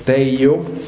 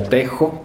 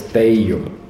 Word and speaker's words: TEJO: Por sus siglas en inglés, TEIYO, o si ustedes lo leen en español sería TEJO, pero es la pronunciación correcta, es TEIYO, ¿no?